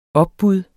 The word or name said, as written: opbud